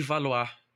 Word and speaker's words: Valois: Atravessando a fronteira de Navarra, nós chegamos à França para falar de Margot de Valois. Valois, a pronúncia afrancesada que nós aprendemos na, na faculdade, mas temos que chamar de Margot de Valo, o Valois, como preferirem.